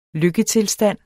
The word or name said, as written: lykketilstand